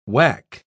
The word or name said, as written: wack